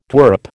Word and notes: twerp: American English

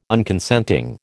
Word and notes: Unconsenting: American English

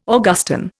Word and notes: Augustan: Indian

